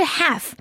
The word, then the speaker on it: half: 자, 친구들 half. H-A-L-F. 그런데 L은 여기서 발음되지 않아요.